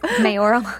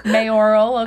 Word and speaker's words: mayoral: And how do you say that? – Mayoral. – Yes. – Mayoral. – Mayoral, okay. That race is over.